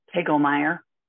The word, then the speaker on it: Kegelmeyer: Kegelmeyer.